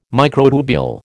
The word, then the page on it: microtubule: American English